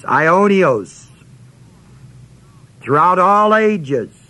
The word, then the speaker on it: Aionios: This word everlasting is the Greek word Aionios, A-I-O-N-I-O-S, A-I-O-N-I-O-S. First O is a long O.